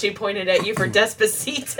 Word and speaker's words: Despacito: I'm glad you're teaching us the right pronunciation. – Despacito. – I could literally say it the exact same way you have, and it won't nearly sound as good.